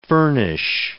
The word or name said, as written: furnish